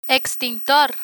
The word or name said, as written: extintor